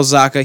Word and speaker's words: Zacha: Well, I mean, there's others, but before we get into something that, Pavel Zacha, I want to make sure that I'm pronouncing that correctly. Yep, Pavel Zacha, he signed with the New Jersey Devils a 3-year, $2.25 million contract.